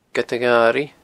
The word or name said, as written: Gead a Gharaidh